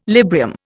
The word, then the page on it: Librium: Indian